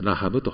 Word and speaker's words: ラハブ: ヘブル語ではアルファベットが一文字違うだけなんですが、読み方は同じです。ラハブと読むんですけれども、女性の名前と海の居住の名前に分けられていて、そして海の居住は特にエジプトの私的名称として、詩の中に使われている名前として、頻度が高いということです。